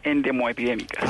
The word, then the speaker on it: endemoepidémicas: endepidémicas del Ministerio de Salud. ¿Lo dije bien? Gracias por estar con nosotros. – Muy buenos días a todos. Son enfermedades endemoepidémicas.